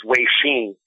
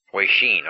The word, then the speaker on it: Weixin: There's an app, and the correct pronunciation for that is Weixin. – Weixin, okay.